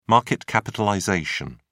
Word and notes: market capitalization: British English

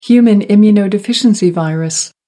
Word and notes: human immunodeficiency virus: American English

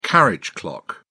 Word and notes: carriage clock: British English

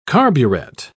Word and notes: carburet: British English

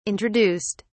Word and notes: introduced: British English

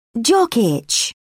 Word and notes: jock itch: British English

